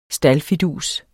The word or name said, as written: STALDFIDUSER